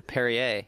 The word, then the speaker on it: Perrier: And the correct pronunciation, too, Perrier.